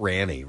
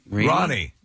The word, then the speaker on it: Rani: Goldie Hawn and her daughter Kate Hudson, with Kate's daughter Ronnie, spelled R-A-N-I. Is that how you would pronounce that? Ronnie? Not Ranny, right? – Ronnie. Just like Ronnie says.